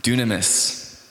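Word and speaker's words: dunamis (δύναμις): And the Greek word for actually ability that's used in that verse 15 is pronounced dunamis.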